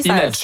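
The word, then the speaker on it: Leenalchi: Oui, on ne prononce pas le L au début, donc c'est bon, déjà, Inalchi. Inalchi.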